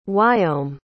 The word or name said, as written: wyome